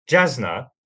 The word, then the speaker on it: Jasna: So Jasna is the correct pronunciation.